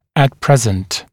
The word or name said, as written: at present